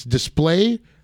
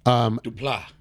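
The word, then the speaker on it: Desplat: Alexander, I think it's Display or Displate. – Dupla.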